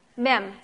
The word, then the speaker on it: Mem: How do we say that? Mem? M-E-M? Mem. Mem. That's Mem.